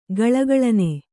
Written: ಘಲಘಲನೆ

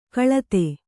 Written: ಕಲಿತ

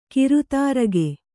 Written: ಗಿರಿದುರ್ಗ

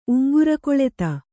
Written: ಉಂಗುರಕೊಳೆತ

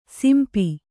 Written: ಸಿಂಪಿ

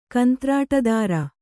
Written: ಕಂತ್ರಾಟುದಾರ

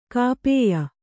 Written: ಗೋಪ್ಯ

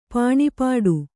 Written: ಪಾಣಿಪೀಠ

